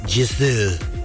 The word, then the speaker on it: Gisou: I don't know how they would, like, put that on here to pronounce, but Gisou. Yeah. Instead of an S, it's more of like a Z. Like, they literally have the word zoo on here.